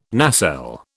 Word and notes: nacelle: British English

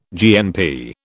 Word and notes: GNP: British English

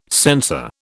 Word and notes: sensor: British English

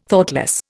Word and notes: Thoughtless: Indian

